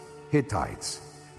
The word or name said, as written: Hittites